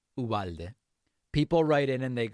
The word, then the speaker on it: Uvalde: I just wanted to let you know that here in Texas, we don't say Uvalde. We say Uvalde. It reminds me of you pal me, you know, it rhymes.